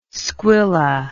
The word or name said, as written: squilla